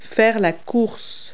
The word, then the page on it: race: verb